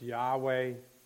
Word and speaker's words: Yahweh: Sometimes referred to as Jehovah, maybe better pronounced Yahweh.